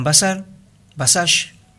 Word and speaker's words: Başar: Un chico jovencito, 20 años tiene este pibe, Hakan Basash, así se parece que se pronuncia,